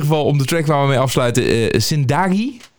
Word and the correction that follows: Zindagi: Ja, Zindagi. Zindagi, oké. ik moet de klemtoon net iets, iets anders uh, zetten.